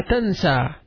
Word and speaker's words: تنسى: لا تنسى. لا تنسى. Don't forget. لا تنسى. تنسى means to forget. تاء نون سين أن ياء مقصورة which is pronounced as an ألف.